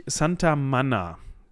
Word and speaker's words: Manna: Auf jeden Fall hat sich dieser Ritus, also daraus hat sich ein Ritus entwickelt und der nennt sich Santa Manna. So würde ich es aussprechen, oder? Ja, doch. Mit Doppel-N, also M-A-N-N-A.